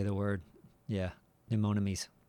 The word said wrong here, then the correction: anemone: Anemones. – Anemone. – Just almost say like I'm your enemy. You're an enemy.